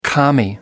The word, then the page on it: commie: American English